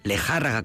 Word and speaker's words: Lejárraga: Lejárraga, como ella escribía con acento en la A.